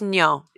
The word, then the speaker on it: nyo: Okay, in Tagalog, is nyo, what's nyo? – N-Y-O.